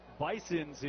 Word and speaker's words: Bisons: Some folks pronounce it like there's a Z in a North Dakota state known as the Bison. And they are aware that Bisons is not, you know, the way that most people would do that.